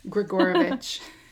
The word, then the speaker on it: Gregorovich: All right, how do I say it? – Gregorovich.